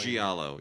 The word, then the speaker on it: Giallo: Giallo? What's the Italian? – Giallo, yes.